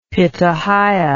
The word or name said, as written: pitahaya